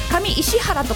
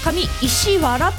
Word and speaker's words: 上石原: もう普段京王線ユーザーだから当たり前のように読めちゃってるけれども、あとなんだ、調布にもあの上に石原軍団の石原、下に石原軍団の石原って書いて上石原とか読みたくなっちゃうところ、上石原って読むんですよね。